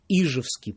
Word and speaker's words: Ижевский: где должно быть ударение в слове, например, «Ижевский». Я ему прочитаю вот здесь вот из песни, видимо, все-таки «Ижевцев», которая написана в 18-м году поручиком Николаем Арнольдом. Вот, пожалуйста. «Кто не слыхал, как с врагами сражался, Ижевский полк под кровавой Уфой, как с гармонистом в атаку бросался, Ижевец, русский рабочий простой». По крайней мере, в 18-м году ударение было именно там, как мы и говорим.